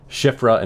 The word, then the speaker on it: Shiphrah: Shiphrah and Puah from that.